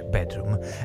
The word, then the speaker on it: BDRMM: si scrive BDRMM, ma si dice bedroom,